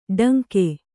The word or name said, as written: ಟಂಕೆ